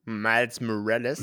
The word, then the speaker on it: Miles Morales: So wird er ausgesprochen? Miles Morales. – Miles Morales, ja, der okay, ist, cool.